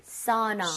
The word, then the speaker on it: sauna: 근데 일반적으로 전 추천하는 것이 AU 혹은 AW라는 스펠링이 보이잖아요. – 예. – 그러면 아우보다는 이런 느낌. – 사우나. – 사우나. – 사우나. – 느낌. 그렇죠? – 예. – 그래서 sounds like 예를 들어서 법 할 때도 AW죠. – 예. – law 이렇게 가듯이 law. – 라우 안 하잖아요.